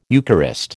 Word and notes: Eucharist: American English

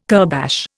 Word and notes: kurbash: Indian

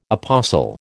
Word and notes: apostle: American English

